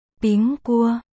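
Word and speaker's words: Bing Cua (苹果): Trong tiếng Trung, quả táo được phát âm là Bing Cua, đồng âm với chữ bình trong từ đêm bình an, Pinya Niye.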